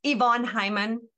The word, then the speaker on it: Yvonne Heimann: It's Yvonne Heimann. It's like Wally, just like the E on the end. That's how I start my name.